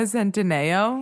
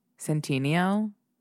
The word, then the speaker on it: Centineo: Is that a name, Noah Centineo? – It's Noah Santa fucking Neo. Noah. – Oh, it is spelled like that, but I do think it's Noah Centino. Centineo. – Centineo, okay. Bitch.